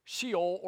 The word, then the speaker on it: Sheol: Sheol is the, Sheol or Sheol, it's pronounced both ways, is a place for the dead.